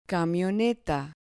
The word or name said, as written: camioneta